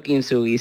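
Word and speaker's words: Kintsugi: Proyecto Kinsugi, sí.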